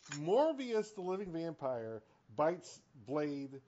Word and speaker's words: Morbius: it's pronounced Morbius. You keep Morbius. – saying Morpheus.